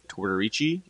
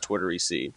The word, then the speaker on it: Tortorici: So half the time I say Tortorici, the other half it's Tortorici.